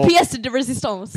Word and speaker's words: pièce de résistance: It was pièce de résistance. It was coming out as... – The pièce de résistance. – Pièce de résistance. – I was adding another syllable, but I couldn't figure out how to not do it. – But now you've got it.